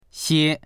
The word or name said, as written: xie1